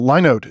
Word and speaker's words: Linode: And Linode is now Akamai Cloud. Linode. I'm trying to change my pronunciation because it's not called Linux. So it's called Linux, therefore must be Linode.